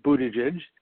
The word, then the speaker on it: Buttigieg: I actually looked that up. That is how you say his last name, Buttigieg.